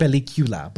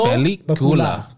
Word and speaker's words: película: No, it's not película. It's película. – Because you got you got to put the right emphasis on the right letters.